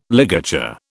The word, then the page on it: ligature: British English